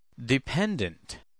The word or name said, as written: dependent